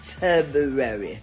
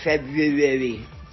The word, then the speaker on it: February: The first R is often overlooked because it's not pronounced. February. And some people say February.